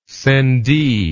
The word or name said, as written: sendee